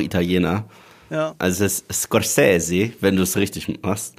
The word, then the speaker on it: Scorsese: hat es auch vor nicht allzu langer Zeit nochmal in einem Interview gesagt, wie man es ausspricht. Also es ist Scorsese.